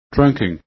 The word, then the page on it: trunking: english